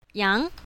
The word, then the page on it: yang2: mandarin